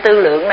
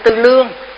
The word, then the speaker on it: Tư Lượng: Vì cái kinh Tư Lượng này còn đọc là kinh Tư Lương, thì hai cái chữ này đó thì chữ nào cũng được.